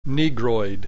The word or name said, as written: negroid